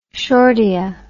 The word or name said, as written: shortia